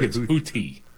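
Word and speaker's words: Houthi: I think it's Huthi. – Huthi, like the blowfish. – Yes.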